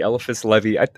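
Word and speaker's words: Éliphas Lévi: Eliphas Levy.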